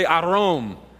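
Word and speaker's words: arom (ערום): Now the Hebrew word there for naked is the word arom. Everybody say arom. No, you gotta roll your R's.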